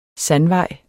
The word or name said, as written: sandvej